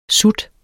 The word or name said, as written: SUTS